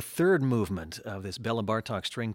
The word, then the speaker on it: Bartók: And interesting to think of that as a Hungarian rhythm in music, because it's almost like Hungarian language, where the emphasis is always on the first syllable, like Béla, Bartók, Zoltán, Kodály.